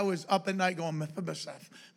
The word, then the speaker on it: Mephibosheth: It's a tongue tire, but it is Mephibosheth. That's the name. That's how you pronounce it. Mephibosheth. That's the proper enunciation.